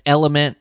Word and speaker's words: LMNT: it's Element. It's spelled L M N T.